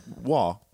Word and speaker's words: Roy: It looks like Roy. – Yeah. – It's not. It's French, and French is a beautiful language.